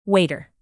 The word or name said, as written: waiter